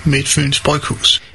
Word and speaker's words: Midtfyns Bryghus: Hi, in Denmark we pronounce Midtfyns Bryghus as Midtfyns Bryghus.